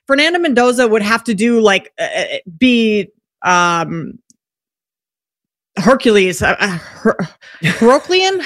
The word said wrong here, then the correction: Herculean: How do you say that word? – Herculean. It would take a Herculean season.